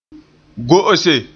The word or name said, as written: go'ose